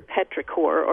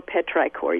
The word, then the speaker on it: petrichor: Well, let me just tell you, so you can remember petrichor or petrichor. You can pronounce it either way.